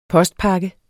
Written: postpakke

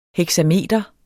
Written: heksameter

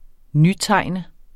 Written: nytegne

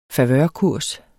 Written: favørkurs

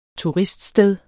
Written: turiststed

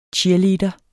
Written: cheerleader

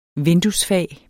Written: vinduesfag

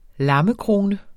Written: lammekrone

